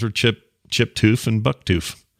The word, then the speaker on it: Toof: He says, I knew of a family in the town I went to college in by the last name Toof, pronounced Toof, T-O-O-F, although pronounced spelled T-O-U-F.